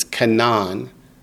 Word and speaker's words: Kenan: This is not Canaan as in the land of Canaan. In the Hebrew Bible, it's spelled K-E-N-A-N. It's Canaan. So it's different, Canaan.